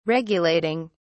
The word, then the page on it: regulating: British English